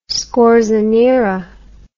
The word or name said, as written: scorzonera